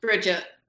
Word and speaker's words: Brigitte: Brigitte. – Brigitte, just plain Brigitte. Okay, more an American pronunciation.